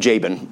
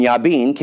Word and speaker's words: Jabin: I had to write that phonetically beside it because I kept wanting to call him Jabin, but it's Yabin.